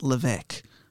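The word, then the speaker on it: Levegh: Also, I've heard it pronounced Levesque, but I will say Pierre Levay.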